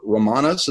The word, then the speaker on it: Romanus: But everybody except Robert Romanus, is that how you say his name? – Sure.